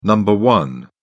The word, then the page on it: number one: British English